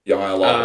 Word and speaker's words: jai alai: It's spelled J-A-I-A-L-A-I. – Jai Lai, that's it.